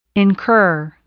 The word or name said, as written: incur